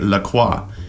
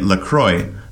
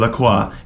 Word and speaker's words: La Croix: In French, you say La Croix. – Right. – If you think of the pronunciation of croissant, you will see that that is the standard pronunciation in French of like a, you know, O-I.